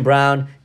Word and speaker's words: Braun: Christian Brown, to me, is the X factor on Kansas. Yeah, it's spelled Braun. It's B-R-A-U-N, but they call him Brown.